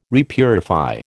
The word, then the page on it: repurify: American English